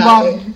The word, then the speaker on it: Nanban: I don't know how you pronounce Nanban. – It. – Nanban. – N-A-N-B-A-N.